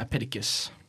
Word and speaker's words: Epictetus: And by the way, we were saying that wrong the whole time. – Yeah. – It's Epictetus, not Epictetus.